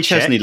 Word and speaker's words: Szczęsny: Say Chesney like Chesney Hawks.